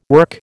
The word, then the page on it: work: American English